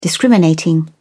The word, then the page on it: discriminating: British English